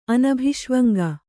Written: ಅನಭಿಷ್ವಂಗ

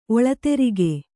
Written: ಒಳತೆರಿಗೆ

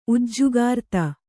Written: ಉಜ್ಜುಗಾರ್ತಿ

